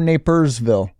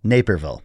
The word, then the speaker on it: Naperville: Is it Naperville or Napersville? – Naperville. – Naperville. I think I say it wrong. – It's named after a gentleman by the name of Tom Naper.